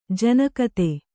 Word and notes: ಜನಕತೆ: janakate

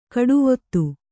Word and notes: ಗಾಡಿವತಿ: Kannada

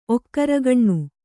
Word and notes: ಒಕ್ಕರಗಣ್ಣು: okkaragaṇṇu

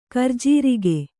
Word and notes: ಕರಿಜೀರಿಗೆ: karijīrige

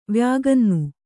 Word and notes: ವ್ಯಾಗನ್ನು: kannada

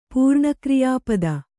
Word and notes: ಪೂರ್ಣಕ್ರಿಯಾಪದ: kannada

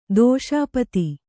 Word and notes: ದಶಪಾದಿ: daśa pādi

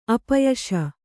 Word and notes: ಅಪ್ಪಯಿಸು: kannada

